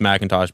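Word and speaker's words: McIntosh: Yeah, it's well, it is pronounced Macintosh, but it's a different company. It's not Apple Mac.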